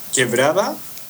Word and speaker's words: Quebrada: I'm going to attempt to do this. Quebrada del Chuca? Is that correct? Or can you pronounce that for me and help me out a little bit? – Quebrada? – Quebrada. – First word? – Yeah, quebrada. – Quebrada. – Quebrada. – Yes, that quebrada means like water creek.